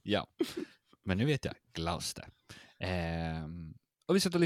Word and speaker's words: Gloucester: Eh, ja, jag var tvungen att, jag googlade hur man uttalar det, och det är Gloucester.